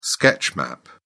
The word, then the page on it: sketch map: British English